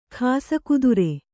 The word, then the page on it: ಖಾಸಕುದುರೆ: khāsa kudure